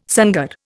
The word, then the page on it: sangar: Indian